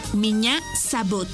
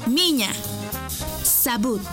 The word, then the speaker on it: меня зовут: Y se escribe P-R-I-V-I-E-T. Привет. ¡Muy bien! Repite conmigo. Привет. ¡Muy bien! En ruso, me llamo se dice меня зовут. ¿Y tu nombre? Una vez más. Меня зовут. Por ejemplo, меня зовут. Esta palabra se escribe меня. M-I-Ñ-A. Меня зовут. Z-A-V-U-T. Зовут.